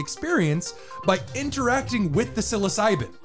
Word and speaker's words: psilocybin: Now, for those who don't know, myself included, obviously, the molecule that produces the psychoactive effect is called psilocybin, I think is how you pronounce it. – Psilocybin. – Psilocybin. You idiot.